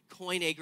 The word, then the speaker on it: Koine: Koine Greek is how you say that word. Koine Greek, which is like a common version of the Greek language.